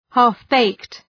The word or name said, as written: half-baked